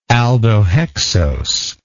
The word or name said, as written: aldohexose